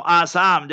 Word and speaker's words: Assam: In Assam, or Assam, that's how you should pronounce it. In English, did you say Assam? That's wrong. Assam is the correct.